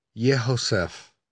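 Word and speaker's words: Yehosef (יהוסף): In the Hebrew text of this psalm, there is an extra letter included in the name Yosef. It's the letter He, so his name is pronounced Yehosef.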